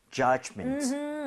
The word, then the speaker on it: judgment: D 사운드는 굳이 세게 낼 필요가 없어요. 그래서 그냥 judge, judgment 이 정도도 충분합니다. – 네. – 오케이? 자, 그러면 judgment 발음 한번 해볼게요. D 사운드 크게 내지 마시고요.